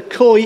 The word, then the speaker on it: Chol: God called them to work amongst the Khoi Indians. Khoi is spelled C-H-O-L, the Khoi Indians in South Mexico.